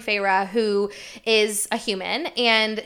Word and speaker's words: Feyre: Two syllables, Feyre, and they spell it out.